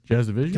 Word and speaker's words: Jasudavicius: I think I said her name right. Jazdavicius. – Yes, you got it.